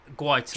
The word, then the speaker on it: Guaita: I haven't got the energy to pull you up on your pronunciation of his name. – Can you? Vicente Gaeta. – Guaita. – Do you want to try it again? – So it's Guaita. – Vicente Guaita.